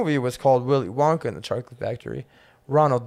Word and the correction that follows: Roald: Dahl actually hated, or Roald Dahl. Roald Dahl. How do you say that for his name? – Roald. – Roald. I always thought it was Ronald until like fucking 3 years ago.